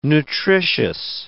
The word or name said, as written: nutritious